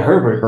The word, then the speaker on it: Herbert: Is that mm-hmm. how you hmm. pronounce his last name? – Yeah, Herbert. Herbert. It's, uh, Herbert.